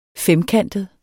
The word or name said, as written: femkantet